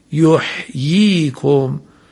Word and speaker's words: یحییکم: یحییکم، این کلمه حاء ساکن بعدش یا مدی درست انجام بشه.